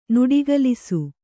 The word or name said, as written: ನುಡಿಗಲಿಸು